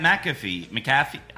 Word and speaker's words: McAfee: Like he wouldn't even interact with Pat McAfee.